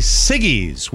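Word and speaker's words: Siggi's: The fancy yogurt company Siggy's will pay one person.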